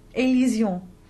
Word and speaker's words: élision: So just to recap the important points, liaison is not the same thing as elision, élision.